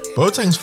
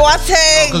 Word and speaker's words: Boateng: Boateng's fine. Boateng is like... – Boateng! – Well, you know, in the Ghanaian way is Boateng, but it's fine.